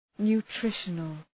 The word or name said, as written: nutritional